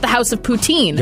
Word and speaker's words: Poutine: And there is a restaurant in France, and they decided their restaurant is called La Maison de la Poutine. And in French, Putin is actually written and pronounced like poutine, exactly like, you know, the signature dish coming out of Quebec.